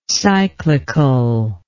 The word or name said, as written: cyclicality